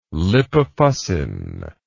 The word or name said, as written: lipofuscin